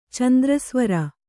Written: ಚಂದ್ರಸ್ವರ

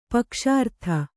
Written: ಪಕ್ಷಾರ್ಥ

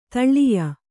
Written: ದೊಳ್ಳಾಯಿ

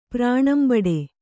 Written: ಪ್ರಾಣಂಬಡೆ

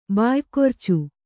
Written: ಬಾಯ್ಕೊರ್ಚು

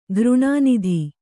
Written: ಘೃಣಾನಿಧಿ

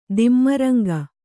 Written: ದಿಮ್ಮರಂಗ

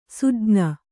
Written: ಸೂಜ್ಞ

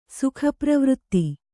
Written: ಸುಖಪ್ರವೃತ್ತಿ

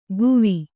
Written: ಗುವಿ

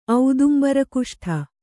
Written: ಔದುಂಬರಕುಷ್ಠ

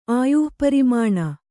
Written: ಆಯುಃಪರಿಮಾಣ

